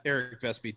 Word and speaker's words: Eric Vespe: My Twitter is at Eric Vespe. Just my name. V-E-S-P-E.